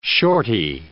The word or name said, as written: shorty